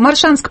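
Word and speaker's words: Моршанск: Вячеслав Маршанск, Тамбовская область. Маршанск, правильно я произношу, Вячеслав? – Да, правильно.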